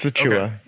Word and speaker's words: Achiuwa: Precious Achiuwa.